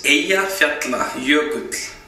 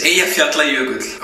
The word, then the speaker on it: Eyjafjallajökull: It's Eyjafjallajökull. That's Eyjafjallajökull. So it's Eyjafjallajökull.